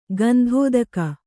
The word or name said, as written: ಗಂಧೋದಕ